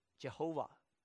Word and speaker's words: Jehovah: 原来的希伯来文只有四个字母，就是 YHWH。那当然这四个字母因为没有元音在里面，所以不能发音，犹太人后来就加了这个另外一个词，就是 Lord 这个词 AOA 三个元音插进去，就变成 Jehovah，中文把它翻作耶和华。